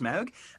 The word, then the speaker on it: Smaug: Smaug. It's fine. It's Smaug. It's pronounced Smaug.